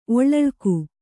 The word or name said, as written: ಒಳ್ಳಳ್ಕು